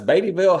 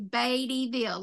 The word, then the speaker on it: Beattyville: Now, if I try to explain or spell it or whatever, I'll say Beattyville, like Warren Beatty, the actor, Beattyville. But locally, it's Beattyville.